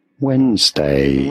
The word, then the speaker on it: Wednesday: Wednesday. – Wednesday. – You could simply, as you can see on your screen, spell it as W-E-N-Z, day and then day. Wednesday.